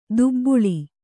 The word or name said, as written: ದಬ್ಬಳ